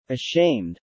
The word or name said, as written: ashamed